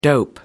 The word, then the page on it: dope: American English